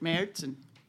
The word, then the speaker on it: Märzen: Homestead Beer Company's Oktoberfest Marzen Lager. Did I do the Marzen right? – With the umlaut, I would pronounce it Märzen.